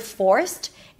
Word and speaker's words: forced: Make sure that when you say the word forced, it doesn't sound like first, because that's a different word. To do that, you want to make sure that the O sound is fully pronounced, full, and then you bring the tongue up for the R only at the end after you pronounce the O.